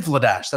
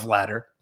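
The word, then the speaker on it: Vladař: It's Dan Vladash. That's how you pronounce his last name, right? – Yes. – In Calgary. It's not Vladder, and he did list Vladash as a possibility for a trade.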